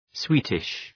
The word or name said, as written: sweetish